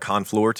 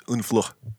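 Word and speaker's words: Honfleur: I had to look up the pronunciation because it looks like Honflor to me. – Unflor. – Obviously, it'd be an American, but it's Unflor.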